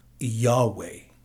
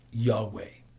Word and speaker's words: Yahweh: So the general consensus of opinion is that the name was to be pronounced Yahweh. Okay? Yahweh. But somewhere along the line, the pronunciation of Jehovah or Jehovah came from it, and it became the more popular. But Yahweh is probably the correct pronunciation, though we don't know for sure.